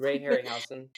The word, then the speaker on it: Ray Harryhausen: Ray Harryhausen.